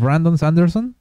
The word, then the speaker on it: Brandon Sanderson: que si dices Brandon, cuesta, pero si es Brandon Sanderson. Brandon Sanderson. Ajá. – Tienes que decirlo con la pronunciación en inglés.